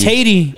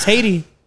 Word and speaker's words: Haydee: H-A-Y-D-E-E. – H-A-Y-D-E-E. – Oh, it is probably Haiti. – It's Haiti. – Yeah. – That's how I pronounce it, bro. – It's Haiti. – Shout out to Haiti. – H-A-Y is hey. D-E-E is D.